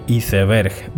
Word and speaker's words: iceberg: Iceberg, we say iceberg, but the word is actually the same as in English. What changes is the pronunciation, you say iceberg, and we say iceberg, ¿vale?